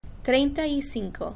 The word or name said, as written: trienta y cinco